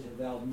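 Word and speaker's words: Havell: I pronounce it Havel. Most Audubon scholars pronounce it Havel. There's descendants of the Havel family who pronounce it Havel. And you might hear that pronunciation. It's never, ever Havel. There's a well-known Audubon biographer who shall go unnamed who goes around saying Havel. And that's just wrong. It's either Havel or Havel.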